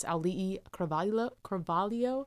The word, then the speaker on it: Auli'i Cravalho: I looked up phonetics. I looked up how do you say her name, and I watched a video of her saying it. It's Auli'i Cravalho.